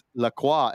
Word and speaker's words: Lacroix: thought it was Lacroix, but it's like it's it's Lacroix, and I go, "How do you get qua from C R O I X?"